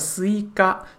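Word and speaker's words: Suica: 呃，在日文里面读起来呢，叫斯伊嘎，斯伊嘎和日文里面的西瓜是同一个读音，所以说我们一般都称它为西瓜卡。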